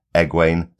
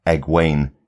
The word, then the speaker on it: Egwene: So I may be changing every spelling of Egwene to Egwayne, so we pronounce it correctly from now on.